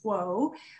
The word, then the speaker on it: Huo: So my last name is pronounced Huo, like Wo with an H.